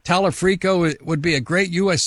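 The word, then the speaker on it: Talarico: If you and I sit here on November the 4th and 5th and say, okay, we got Dr. Abdul, we have Tallarico, we have Angie Nixon, maybe do you and I not perceive reality? Is that possible? – I think you're mispronouncing his name. It's Talafrico.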